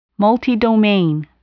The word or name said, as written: multidomain